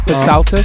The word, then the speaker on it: Psaltis: Psaltis, sorry, I've been told how to pronounce it correctly.